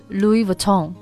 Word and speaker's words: Louis Vuitton: 对、而且要注意这个地方的虽然它的拼写是 L-O-U-I-S、但是这个 S 是不要发音的 silent S、然后这个重音也要放在这个 tone 这里，所以其实它正确的读法应该是 Louis Vuitton.